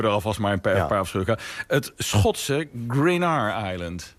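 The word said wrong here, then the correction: Gruinard: Ja, volgens mij spreek je uit als Greenyard. – Greenyard, Het is een vrij bescheiden eiland.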